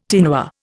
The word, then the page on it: tinware: Indian